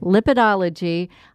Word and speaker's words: Lipidology: Is that I say it right? – You're right. – Lipidology.